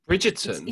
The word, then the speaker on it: Bridgerton: Bridgerton? – Is it Bridgerton? Yes, yes, you say it so right. You pronounce it the English way.